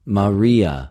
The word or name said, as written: Μαρια